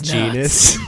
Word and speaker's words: Janus: It's pronounced genus.